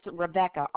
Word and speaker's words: Rebecca: My name is Rebecca, R-E-B-E-C-C-A.